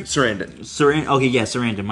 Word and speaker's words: Sarandon: Okay, the vampire that was played by Chris Sarandon, I think I said his name right? – Sarandon, my bad.